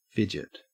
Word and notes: fidget: Australian English

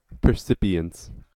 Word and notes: percipience: American English

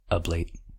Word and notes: ablate: American English

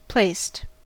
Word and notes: placed: American English

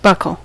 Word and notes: buckle: American English

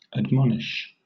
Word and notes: admonish: British English

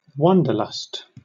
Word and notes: wanderlust: British English

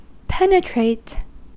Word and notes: penetrate: American English

